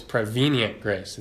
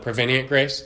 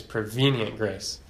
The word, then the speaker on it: prevenient: And technically, it's prevenient grace. It's like a veen in there. I always called it prevenient grace. It's easier, but so if you catch me, it's prevenient grace.